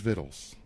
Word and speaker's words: victuals: It is Vittles, but the proper way to spell it is Victuals. – I've been spelling Vittles as V-I-D-D-L-E-S, like a dummy all these years.